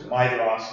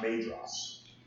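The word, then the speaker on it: Maedhros: which I will do if you mispronounce Midros, which is Midros, not Madros.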